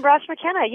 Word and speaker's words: Brosh McKenna: Is it Elaine Brosh McKenna? That's how you say it? Elaine Brosh McKenna, yes.